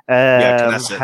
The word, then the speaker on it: Knesset: The parliament or the Knesset, right? Is that how you say it? – Knesset.